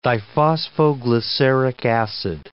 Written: diphosphoglyceric acid

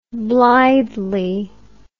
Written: blithely

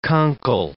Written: conchal

